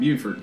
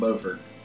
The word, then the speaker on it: Beaufort: And if you're from North Carolina, it's pronounced Beaufort, not Beaufort, down here.